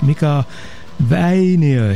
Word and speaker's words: Mika Väinö: So here's Mika Väinö here at KFJC.